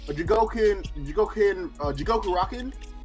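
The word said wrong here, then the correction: Jigokuraku: Or Jigoku Raku, how it's pronounced, the Hell's Paradise joint.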